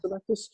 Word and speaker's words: Zemeckis: Is it Robert Zemeckis? Is that how you pronounce it? – Yeah. – Zemeckis.